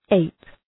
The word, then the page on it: ape: eıp